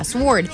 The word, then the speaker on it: sward: Yeah, sword. But sword speak is... how we call gay lingo. – Gay lingo. – How do you spell it? S-W-A-R-D. – Like sword, but with an A.